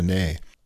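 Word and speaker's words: ney: Last week, we had a really interesting recording from clarinetist and how do you say that? Nay, neighbor? Nayist? Someone who plays the nay. – Oh, the nay.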